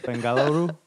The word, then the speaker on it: Bengaluru: So this story comes from Bengaluru, India. Did I say that right? – Bengaluru? – Yes.